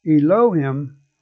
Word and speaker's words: Elohim: And the original language word for the Genesis chapter 1 up through Genesis 2, verse 3, pronounced Elohim, is spelled E-L-O-H-I-M.